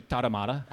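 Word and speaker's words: Tarahumara: I mean, again, in the Spanish, they say it so fast, but they say Tara Mara.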